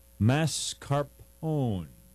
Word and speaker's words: mascarpone: So we're going to talk about the cookies and cream mascarpone.